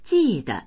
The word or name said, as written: ji4de